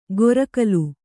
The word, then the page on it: ಗೊರಕಲು: gorakalu; kannada